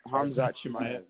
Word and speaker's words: Hamzat Chimaev: Yeah, they're also talking about John Jones and Shamat. All right. How you say it? – Oh, Hamzat Chimaev. Yeah.